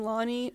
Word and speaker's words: Lani: My website's Shireen Lani, L-A-N-I, S-H, well, my first name is S-H-E-R-E-E-N.